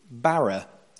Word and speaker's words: bara: The fifth word in the original Hebrew is bara. Everyone say this, please. Bara. There's a slightly different pronunciation if you're a Hebrew scholar, but we're not going to get into that.